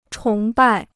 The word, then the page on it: 崇拜: chóng bài